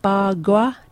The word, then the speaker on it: bagua: The most common being ba gua. Do you think I've pronounced that right? Bagua. – Sounds about right. – B-A-G-U-A.